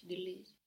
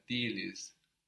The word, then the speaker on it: değiliz: And the vowel should be a little longer. Değiliz. – Aç değiliz. – Good. Because it's a contraction of değil, the E, the soft G, and the I. So whilst they all got contracted, that vowel still stayed long. Değiliz.